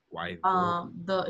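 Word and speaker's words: Guaidó: Guaido. – He has an accent on the O, so I'm like...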